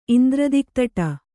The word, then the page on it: ಇಂದ್ರದಿಕ್ತಟ: kannada